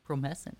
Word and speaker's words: Promescent: We would say that our show today is brought to you by Promescent. – Promescent.